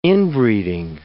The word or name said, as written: inbreeding